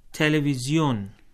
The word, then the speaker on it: تلویزیون: تلویزیون جی، television کا فارسی تلفظ۔